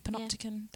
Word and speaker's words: Panopticon: Panopticon.